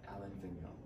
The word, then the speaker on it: Alain Vigneault: Alain Vigneault, that's how you say it.